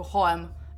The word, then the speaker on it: H&M: czyta się jako Hoem I teraz tak mówię i nikt nie wie o co mi chodzi, jak mówię, że pójdziemy do Hoemu.